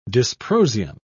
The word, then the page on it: dysprosium: British English